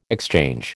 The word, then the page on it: exchange: American English